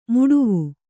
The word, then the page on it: ಮೊಡವು: moḍavu